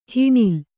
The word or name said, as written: ಹಣ್ಣೆಲೆ